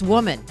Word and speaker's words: womxn: It's pronounced the same as woman. I looked up the pronunciation of W-O-M-X-N, and it's woman.